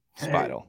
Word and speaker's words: Speidel: So let's get the man in the house, ladies and gentlemen, Mr. Tanner Speidel.